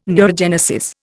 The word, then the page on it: neurogenesis: Indian